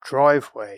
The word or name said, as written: driveway